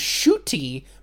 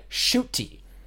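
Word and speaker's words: Ncuti: And his name is Shuti, but it is spelled Nukuti, but it is said Shuti.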